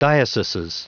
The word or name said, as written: dioceses